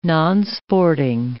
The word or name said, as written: nonsporting